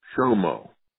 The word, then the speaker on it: SHOMO: which the authors referred to as SHOMO.